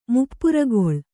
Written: ಮುಪ್ಪುರಿಗೊಳ್ಳು

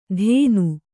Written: ಧೇನು

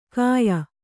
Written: ಕಾಯ

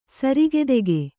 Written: ಸರಕುದೆಗೆ

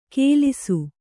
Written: ಗೆಲಿಸು

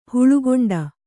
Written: ಹುಳುಗೊಂಡ

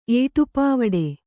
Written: ಏಟುಪಾವಡೆ

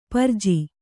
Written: ಪರಿಜು

